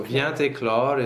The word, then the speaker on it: vient éclore: Okay, so I interrupted all of that so why do we make the liaison between vient So vient éclore is a verb group. The two words are related. The T is silent. So the liaison is compulsory in this case.